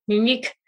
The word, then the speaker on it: Muniq: So if you are familiar with the, am I saying that correctly? Munique, M-U-N-I-Q. – Yeah, Munique. It is a Super Gut formula.